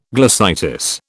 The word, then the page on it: glossitis: British English